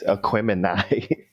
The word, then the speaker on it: Aquemini: But I'm looking at Outkast, Aquaminium. I never can pronounce that right. But their second album came out, and that album was very strong. It did very well. – It's Aquemini. – Aquemini, thank you.